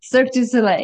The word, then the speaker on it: Cirque du Soleil: I hope I pronounced it right. Forgive me if I didn't. – Cirque du Soleil. Yeah, it's close. It's good.